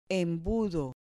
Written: embudo

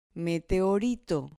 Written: meteorito

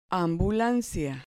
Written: ambulancia

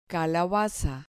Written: calabaza

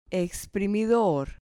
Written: exprimidor

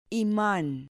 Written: iman

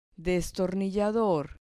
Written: destornillador

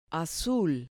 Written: azul

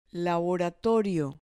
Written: laboratorio

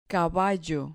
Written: caballo

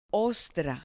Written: ostra

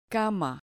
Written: cama

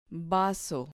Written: vaso

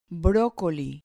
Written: brocoli

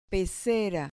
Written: pecera

